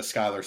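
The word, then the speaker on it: Schuyler: She does get some great songs in this, especially the opening song with the, is it Skylar or Schuler? – It's Skylar. Sisters. It's spelled like Schuler, yeah. But it's, yeah, Skylar Sisters.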